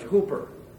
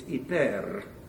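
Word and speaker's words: Hyper (ὑπέρ): You'll hear it pronounced by those who don't know how to pronounce the Greek language. Hooper. Okay? That's not hooper. It's hyper. Okay? Roll the rrr on the end.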